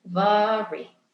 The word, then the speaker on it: Mhairi: Sunday Times bestselling author Vari McFarlan was born in Scotland, and her unnecessarily confusing name is pronounced Vari.